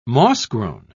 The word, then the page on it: moss-grown: British English